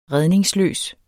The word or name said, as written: redningsløs